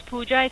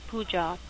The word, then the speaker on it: Pooja: My name is Pooja. It's spelled like peas and Peter. O-O-J-A, Pooja.